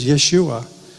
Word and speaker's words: Yeshua: And the pointing tells you how to say it. It says Yeshua.